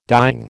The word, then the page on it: dying: American English